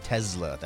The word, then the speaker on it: Tesla: Looking at the shares of Tesla, and that's how you pronounce it, Matt. Tesla. That's how Elon Musk pronounces it.